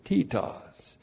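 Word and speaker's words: titos (Τίτος): You call them Titus, but in the Greek they pronounced it titos.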